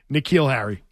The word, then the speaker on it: N'Keal Harry: N'Keal Harry is how you say it.